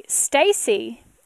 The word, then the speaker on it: Stacy: Stacy is the easy part, S-T-A-C-Y, no E.